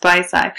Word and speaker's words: Vici: Spelled Vici. Right. But it's pronounced Visai.